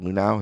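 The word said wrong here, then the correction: Luhnow: is that right? – It's Luno.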